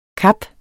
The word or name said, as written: kap